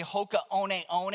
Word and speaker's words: Hoka One One: Hoka 1-1, what is it? Life-changing. Okay? If you're really into them, you know that I just said the name wrong. It's actually Hoka One One, and they will change your life.